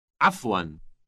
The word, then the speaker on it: عفواً: عفواً. عفواً. – Note that the first sound is produced in the throat.